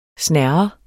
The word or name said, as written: snerre